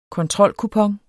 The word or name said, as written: kontrolkupon